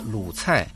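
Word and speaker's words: 鲁菜: Она называется Луцай. Лу – это сокращенное название провинции Шандong. Читается в третьем тоне. Цай означает блюдо, кухня. Читается в четвертом тоне.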